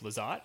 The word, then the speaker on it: Lizotte: Yeah, he's played most of his games this year on that second line with Tyler Toffoli and Blake Lizotte, is that how you say it? – Yep.